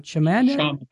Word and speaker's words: Chaminade: How do I pronounce that last name? – Chaminade. – Okay, Chaminade.